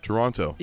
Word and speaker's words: Toronto: Because they actually pronounce it Toronto. – Yeah, so I went and I found this is just one clip that has got people messed up. – Our only way to find the man from Toronto is if you continue to be the man from Toronto. – So the really hard pronunciation on the T? – Yeah.